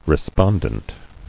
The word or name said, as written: Respondency